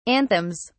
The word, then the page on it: anthems: British English